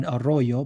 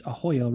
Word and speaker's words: Arroyo: So I was like, my instinct is to roll the R in Arroyo, but I think it's Ahoyo, right? She's like, yeah, that's the rule.